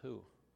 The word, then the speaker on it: He: His name is they spell it H-E, but they pronounce it who.